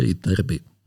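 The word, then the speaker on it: Ytterby: Podle tohoto naleziště vlastně většina těch prvků nese jméno, protože to bylo u švédské obce, jejíž jméno asi neumím vyslovit správně švédsky. Švédská výslovnost je mnohem komplikovanější, než se zdá na první pohled. Ale většina lidí, které znám jak u nás, tak třeba v anglosaském světě to vyslovuje prostě Iterby.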